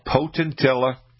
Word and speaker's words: Potentilla: It's called Potentilla, and it's spelled P-O-T-E-N-T-I-L-L-A, Potentilla.